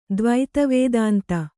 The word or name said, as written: ದ್ವೈತವೇದಾಂತ